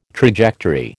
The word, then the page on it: trajectory: American English